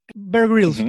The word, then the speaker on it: Bear Grylls: Suponte que eres Grill Bears. Bear Grills, perdón, lo dije mal.